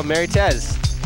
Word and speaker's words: Marites: How do you pronounce this? M-A-R-I-T-E-S? – Oh, Mary Tez.